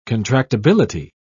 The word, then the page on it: contractibility: British English